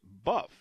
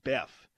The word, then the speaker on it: Boeuf: The other one is what is B-O-E-U-F, which down south, that's pronounced buff. It's meaning beef, French for beef. But they pronounce it Beff.